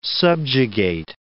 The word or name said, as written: subjugate